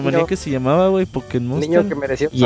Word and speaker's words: Pokémon: Y sí, es Pokémon con acento en la E y se pronuncia como lengua española. Saludos de su amigo Aldeborán. – Pokémon.